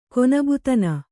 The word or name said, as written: ಕೊನಬುತನ